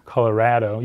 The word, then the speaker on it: Colorado: Colorado, you don't say avocado, right? So it should be Colorado, like avocado, like tostado, right?